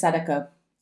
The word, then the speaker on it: Sadako: Yes, it is Sadako.